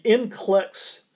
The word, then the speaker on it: NCLEX: In the NCLEX test, I may not be pronouncing it exactly right. What is that? – That's correct.